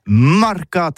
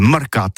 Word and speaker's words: marcat: Eh bien, d'ici à toutis, le mercato, le mercat de l'Uni ou le mercat des rebelles, mais en gascogne toulousano, c'est prononcé sur les marcat avec un A. Marquette. En gascogne toulousaine, bah, du côté par exemple de l'île des Baches, c'est l'île Jourdain, pour parler du marché, vous prononcez les marcat.